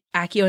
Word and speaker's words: Accio: And it is Accio, and then you say whatever you need, and it brings it right to you.